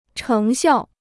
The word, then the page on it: 成效: chéng xiào